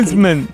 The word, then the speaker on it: msemen: Es una palabra complicada, decía, es M-S-E-M-E-N. Msemen, msemen, vale.